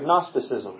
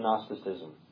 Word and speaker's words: Gnosticism: So they combining this philosophy with the teachings of the New Testament produced the heresy of Gnosticism.